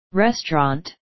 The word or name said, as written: restaurant